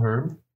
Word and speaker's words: herb: And that is a magical herb. – Yeah, and we do sell them. – Do you pronounce the H? – Yes.